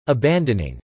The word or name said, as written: abandoning